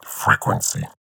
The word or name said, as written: Frequency